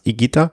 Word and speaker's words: Higuita: Also das H ist stimmlos und das U ist stimmlos. Hat mir mein kolumbianischer Kollege erklärt. Und er hat dann auch noch die Bonusinformation gebracht. Gibt's einen Fußballtorhüter, der dann auch immer falsch ausgesprochen wird. Weil die meisten Reporter sprechen den falsch auf. Also das heißt Higuita, also stimmloses H und stimmloses U.